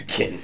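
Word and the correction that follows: Katan: It's pronounced Catan. – Catan with a K.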